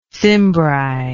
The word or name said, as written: cimbric